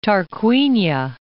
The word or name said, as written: Tarquinia